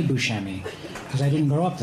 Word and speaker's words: Buscemi: He pronounces it Buscemi. – That's fine. And he can pronounce that around family and friends. But as an actor, he's Steve Buscemi.